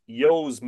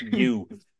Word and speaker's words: ewe: The U in Ireland, most farmers pronounce that EWE. Most farmers in Ireland pronounce that yo, Yo. – but we most of us heathen unfarming types would non-farming types would call it you.